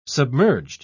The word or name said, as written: submerged